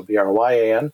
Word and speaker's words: Bryan: Though my first name is spelt with a Y, so Bryan.